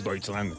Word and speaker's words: Deutschland: Deutschland, ell dit, però és Deutschland, clar.